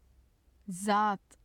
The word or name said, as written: zat